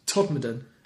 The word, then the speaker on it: Todmorden: So how's it pronounced? – Todmorden.